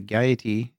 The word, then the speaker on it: Gaiety: He will, live from the stage of the Gaiety in Ireland.